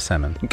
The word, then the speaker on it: salmon: Just say salmon. – Go to Oxford and you tell them that there's no L in salmon.